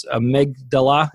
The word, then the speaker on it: amygdala: And you use a term, again, it's amygdala.